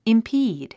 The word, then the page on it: impede: impíːd; インピード